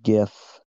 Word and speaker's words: GIF: Hard G. GIF.